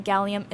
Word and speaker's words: Gxllium: And the one before that was Who Killed Cassie by Gallium off of Gallium 1. And just FYI, Gallium is spelled G-X-L-L-I-U-M.